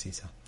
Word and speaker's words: Esau: O my father, and Esau, I don't know if I've been pronouncing that differently. It's Esau, by the way. Esau.